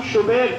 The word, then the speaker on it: شبیر: حضرت ہارون کے بڑے بیٹے کا نام شبر تھا، چھوٹے بیٹے کا نام شبیر تھا، جو ہمارے محافل میں شبر اور شبیر ہو گئے ہیں،